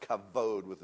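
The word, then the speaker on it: kavod: It's pronounced kabod with a V.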